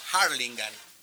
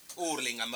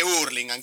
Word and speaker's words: Hurlingham: Hay una anécdota que siempre lo cargaban a Luca porque le decían, ¿cómo se dice Luca? ¿Hurlingham o Harlingham? Hurlingham, papá. Y eso que él había estado en una escuela inglesa. Acá en Argentina se dice Hurlingham, ¿qué es Harlingham? – Bueno, así el 31 que...